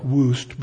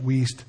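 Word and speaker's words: Wuest: Kenneth Wiest, it looks like Woost, but I'm told it's pronounced Wiest.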